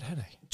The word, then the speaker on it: Henne: Henne, like, I'm obviously pronouncing it like Penne the pasta, which I don't think is right, but yeah, it is. It's Chad Henne.